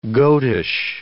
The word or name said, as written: goatish